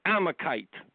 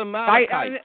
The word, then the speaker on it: Amalekite: Yeah, just like when she wants to pronounce the word Amalekite, she goes Amakite. – Yeah. – And David was fighting the Amakites. – Mm-hmm. – No, Dr. Paula, no. It's a Amalekite.